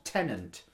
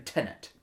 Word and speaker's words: Tenet: Tenet. – It is not Tenant. – No, it's not Tenant. It's not no second N. Tenet. – More people have said the word Tenant to me than Tenet.